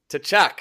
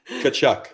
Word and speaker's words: Tkachuk: This is Brady Tkachuk. – Nope. – Tkachuk. – Tkachuk. – Tkachuk. – The T almost doesn't exist.